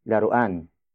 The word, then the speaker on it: laruan: Nang is the direct object marker or the recipient of the action, which is laruan. Laruan. Laruan. Laruan is spelled as L-A-R-U-A-N.